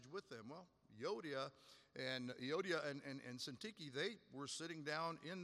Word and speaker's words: Syntyche: If everybody's focusing on that one, he says, Yodia and Syntyche will not have now, you may get a pronunciation. That's how I pronounce it simply because I do read Greek, so that's my pronunciation. Syntyche, what a name, right?